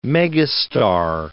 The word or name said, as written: megastar